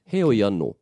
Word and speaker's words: hoi anō: There's a few ways that this kupu can be pronounced. It is just depending on the speaker and where they come from. So hoi could be said hoi, hoi, hoi, hoi, hoi anō.